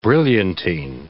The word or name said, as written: brilliantine